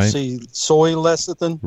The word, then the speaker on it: lecithin: I just want to call in and let you know that the word is, it's pronounced lecithin.